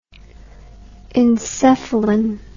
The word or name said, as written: encephalin